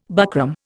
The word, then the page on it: buckram: Indian